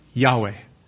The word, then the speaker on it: Yahweh: So the word that's really supposed to be there is the name that God gave Moses for Himself, which we pronounce Yahweh. The name Yahweh.